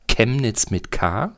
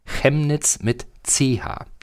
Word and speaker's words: Chemnitz: die Aussprache allerdings nicht Chemnitz mit K sein soll, sondern Chemnitz mit CH. Das ist aber auch ein bisschen strittig, weil im 16. Jahrhundert hat man danach vollzogen, dass der Familienname seiner Familie dann noch Chemnitz mit K ausgesprochen wurde, aber er wollte Chemnitz.